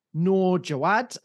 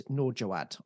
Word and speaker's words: Noor Jwad: As you would have heard from the introduction to this episode, I'm talking to Noor Jwad, and I did pronounce that all correct. Noor Jwad, I got that right.